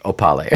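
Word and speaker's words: Opahle: But in there, finally we hear the pronunciation of Florian's last name, his surname. – Oh my gosh, and what is it? – It is exactly how we've been saying it, Opale.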